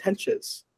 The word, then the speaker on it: Hentges: I'm saying is it Hented? – It's Hentges.